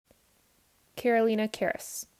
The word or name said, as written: Karolina Karas